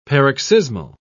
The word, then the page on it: paroxysmal: British English